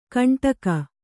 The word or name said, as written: ಕಂಟಕ